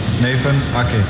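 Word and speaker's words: Nathan Aké: Nathan Ake.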